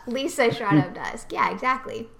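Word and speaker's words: Liesa: It's Lisa, Germanic pronunciation.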